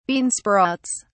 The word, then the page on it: bean sprouts: British English